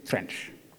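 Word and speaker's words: Trenc: Je crois qu'il faut prononcer Trench.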